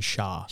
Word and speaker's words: Shah: According to what was said about this, Persian Shia... – Persian Shah.